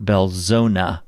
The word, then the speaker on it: Belzoni: First, the town Belzoni, Mississippi is pronounced Belzona, by all the locals.